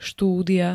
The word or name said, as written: štúdia